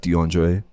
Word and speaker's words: Dxvndre: But, So my name is actually pronounced Dxvndre.